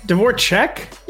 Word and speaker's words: Dvorchak: It's more like an E. It's like an EK. – Pronounce like I-C-K and it's like E-E-K, but like... I don't really care. – So it's Dvorchak? Dvorchak? – Yeah.